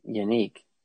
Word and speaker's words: Yannick: I thought I heard in pronunciation guides it is Yannick. Yannick Ngakwe.